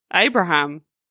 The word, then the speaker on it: Abraham: Abraham.